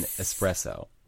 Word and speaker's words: espresso: There's no X in espresso.